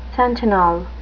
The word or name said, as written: sentinel